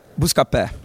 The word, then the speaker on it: Buscapé: So I don't want to butcher the company name, Buscapé. – Yeah, that's right. Buscapé.